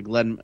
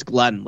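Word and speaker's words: Ghislaine: Glenn. Just so you know how they say her name in court, it's Glenn, like a dude.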